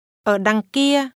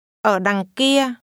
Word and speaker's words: Ở đằng kia: Ở đằng kia. Kia. Đằng. Đằng. Đằng kia. Ở. Ở đằng kia. Ở đằng kia. – This is pronounced with falling, rising. Low falling. – Đằng. – And mid-level tones.